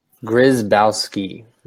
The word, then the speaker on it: Grzybowski: Grzybowski.